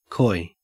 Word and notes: coy: Australian English

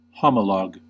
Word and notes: homolog: American English

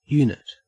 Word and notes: unit: Australian English

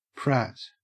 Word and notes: prat: Australian English

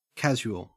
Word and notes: casual: Australian English

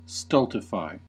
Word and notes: stultify: American English

